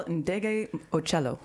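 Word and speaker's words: Ndegeocello: You don't have to say who you are just yet, but special help from one of our three special guests here to pronounce that last name for me. – Yeah, it's Michelle Ndege Ocello.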